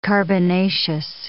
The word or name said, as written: carbonaceous